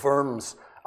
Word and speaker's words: Worms: worms, or worms, as the Germans would pronounce it.